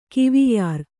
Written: ಕವೆಯರ

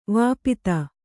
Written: ವಿಪತ್ತಿ

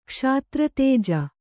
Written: ಕ್ಷಾತ್ರತೇಜ

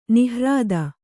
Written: ನಿಹ್ರಾದ

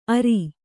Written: ಅರ

